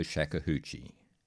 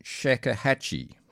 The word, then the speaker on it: shakuhachi: or Grandmaster of the Shakuhachi. Sorry, I'm mispronouncing that. Shakuhachi.